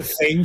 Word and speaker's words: Zenj: En la segunda parte del libro se entra también muy en detalle en la tierra de los Zeng, no sé si lo pronuncio, sí, bien, Zeng, Z-E-N-J,